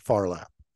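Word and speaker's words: Phar Lap: And it was done in 1983, and it's spelled PHARLAP, Farlap.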